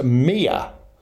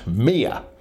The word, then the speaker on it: mio: And in Neapolitan, the word spelt M-I-O is pronounced Mia, as if it's got a, let me see, a Y-U-H at the end, Mia.